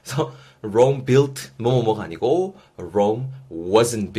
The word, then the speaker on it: Rome: Rome wasn't built in a day. 제가 먼저 한번 해봤습니다. 여러분도 한 번씩 내뱉어 보실 건데요. Rome이라고 발음하시기보다는 Rome, Rome 중간에 우 사운드 있다라는 거.